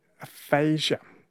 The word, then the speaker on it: aphasia: And I've always heard it pronounced as aphasia, and I used to be a nurse. – I mean, maybe there's different pronunciations across the spectrum of countries and subcultures, but in the trivia source, it was saying aphasia.